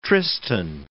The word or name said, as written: Tristan